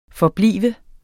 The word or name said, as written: forblive